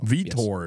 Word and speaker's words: Vitor: The co-main event in the light heavyweight division, we have Victor Paterno. Is that how you pronounce it? – Paterno? Vitor Paterno. – Vitor. Yes.